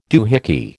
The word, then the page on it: doohickey: American English